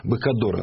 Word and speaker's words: Быкодор: Быкодор она произносилась. Не Быкодер, а Быкодор.